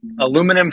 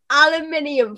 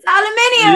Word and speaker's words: aluminium: Aluminum foil. – No. Oh, Lord. It's aluminium foil. – It's aluminium. – Yeah, no. Aluminium. I can get on board with aluminium.